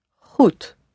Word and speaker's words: goed: So the word good in Dutch is goed. Goed, spelled G-O-E-D. Though the O sound is always spelled O-E. The D at the end is softened to a T sound. Goed.